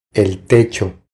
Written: El techo

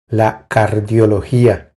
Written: La cardiologia

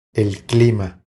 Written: El clima

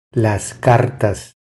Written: Las cartas

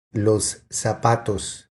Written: Los zapatos

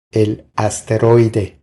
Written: El asteroide

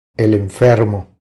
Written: El enfermo

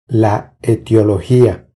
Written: La etiologia

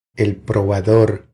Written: El probador

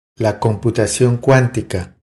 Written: La computacion cuantica

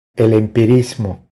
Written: El empirismo

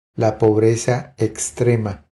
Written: La pobreza extrema

